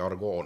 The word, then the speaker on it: argot: ja ta ütleb niimoodi, kootikunst, prantsuse keeles siis art gotique, ja ta taandab selle sõnale argoo ehk kui me vaatame kirjapilti, siis on argot nagu on ju, ütleme, aga häälduse järgi argoo nagu.